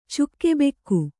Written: ಚುಕ್ಕೆಬೆಕ್ಕು

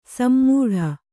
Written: ಸಮ್ಮೂಢ

